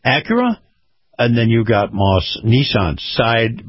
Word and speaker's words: Maus: is brought to you by our friends at Moss Acura. Moss is spelled M-A-U-S.